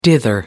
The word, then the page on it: dither: American English